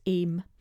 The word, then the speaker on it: AYME: I'd met Jill via the Association of Young People with ME, A-Y-M-E pronounced AIM, which she had founded, and I would later go on to be involved in as their fundraising officer.